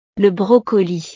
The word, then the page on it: le brocoli: frances